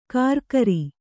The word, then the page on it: ಕರಕರಿ: karakari